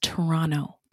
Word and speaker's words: Toronto: And it's pronounced Toronto. That's what the natives say.